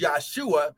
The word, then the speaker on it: Yahshua: His earthly ministry name was Yahshua. We like to pronounce it as Jesus, but there's no J in the Hebrew language.